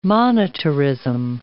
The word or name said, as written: monetarism